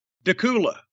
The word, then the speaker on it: Dacula: Dacula. – Dacula. Spelled like Dracula without the R, so I thought Dacula. It is.